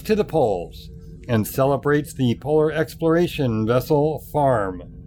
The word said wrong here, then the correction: Fram: There's your shout-out. – Fram. – But you know what? It's a extra special Jeff promo when it's mispronounced.